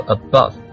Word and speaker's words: above: 但其实呢这个 above 那个啊它是一个中位元音，就是说它的那个发音位置应该是和那个 schwa 这样的发音位置是一样的，它并舌位是一样的，不是发音位置，舌位是一样的。